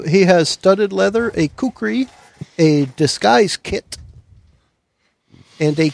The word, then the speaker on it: kukri: It's spelled K U K R I.